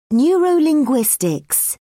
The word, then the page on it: neurolinguistics: British English